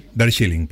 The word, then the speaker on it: Darjeeling: Eh, después vamos a la India, viaje a Darjeeling, dice, ¿cómo se dice? Darjeeling, ¿cómo es? Darjeeling. Darjeeling, perfecto, gracias.